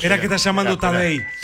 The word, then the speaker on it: Tadej: Tadej, como Tadeo, pero Tadej Pogačar se escribe.